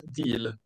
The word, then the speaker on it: Diel: Uttalar jag det rätt förresten? Schlossgut Giel, Rosé de Giel. – Det, det, det, ska nog vara Schlossgut Deal faktiskt, deal. som i engelskans deal.